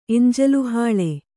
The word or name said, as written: ಎಂಜಲುಹಾಳೆ